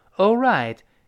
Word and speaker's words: alright: It is 中间那个 t 浊化了，就 It is all right. A L R I G H T，这是拼成了一个词。A L R I G H T，注意重音在后面，我们读成 All right，all right，注意那个 right 字母 r 勾舌头，不要读 all right，不行啊。